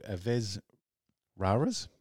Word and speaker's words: Aves Raras: And now they are an Italian cosmic couriers, and they return with their fifth album called Aves Raras. I think that's how you say it. Raras. Rara.